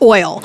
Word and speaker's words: Oyl: So Andrea Naaman is the creator of a line of non-toxic natural skincare called, it's spelled O-Y-L, it's oil.